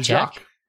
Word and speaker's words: Jacque: So first of all, his first name, it's spelled J-A-C-Q-U-E. So I don't know how to say that. – I'm going to call him Jacques. – I like that you said Jacques.